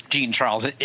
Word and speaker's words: Jean Charles: I think though they give It is Jean Charles. It's not the French pronunciation of that.